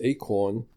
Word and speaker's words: ACRN: Project ACRM, pronounced ACORN, will offer hypervisor and its device model complete with rich I/O mediators.